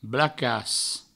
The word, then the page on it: blacas: Provençal